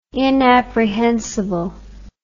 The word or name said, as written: inapprehensible